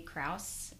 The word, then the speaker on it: Krause: Hi guys, I'm here in Garden City, Idaho today with Melanie Krause.